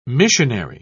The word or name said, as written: missionary